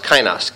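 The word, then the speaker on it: kainos (καινός): There's neos, and there is the word that's used here, the word that is pronounced kainos, K-A-I-N-O-S.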